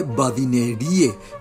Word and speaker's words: badinerie: Badinerie se escribe badinerie y solemos pronunciar en castellanizado badinerie en español.